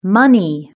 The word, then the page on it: money: British English